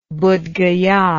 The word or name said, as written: buddhgaya